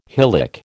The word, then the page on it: hylic: American English